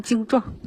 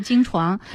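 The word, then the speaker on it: 经幢: 经常会读成精壮。 – 对对对，实际上它是精床。比如说房山区有这个辽天庆六年这个忏悔正慧大师移行塔，虽然叫塔，其实也是塔式的精床。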